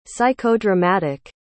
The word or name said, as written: psychodramatic